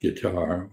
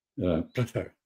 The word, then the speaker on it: Qatar: First, I wish people would learn to pronounce Qatar. It's not guitar or Qatar, which is a chest infection. Qatar is the way it's pronounced.